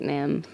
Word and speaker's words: NAMM: How do you pronounce it? – I think it's NAMM. Everyone calls it NAMM.